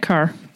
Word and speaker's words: Kerr: Kerr. Um, I believe it's pronounced car, even though there's no A in it.